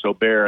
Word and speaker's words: Åberg: It's spelled A-B-E-R-G, but it's pronounced Åberg, and he's from Sweden, 24 years old.